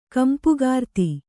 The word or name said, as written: ಕಂಪುಗಾರ್ತಿ